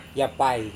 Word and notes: yapai: Serewen

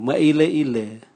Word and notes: mwaileile: Raga

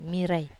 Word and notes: marai: Wamesa

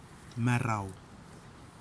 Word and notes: marao: kwamera

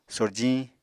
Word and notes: sorjiñ: Apatani

